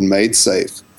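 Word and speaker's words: MaidSafe: There's a whole project called MadeSafe, which is spelled MAID safe.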